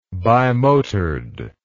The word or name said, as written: bimotored